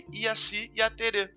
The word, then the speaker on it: Jaci Jaterê: Era Jaci Jaterê. Jaci Jaterê, é como se escreve, mas a pronúncia e também em outros, em outros lugares, de outras regiões, ele também era chamado de Iaci Yaterê.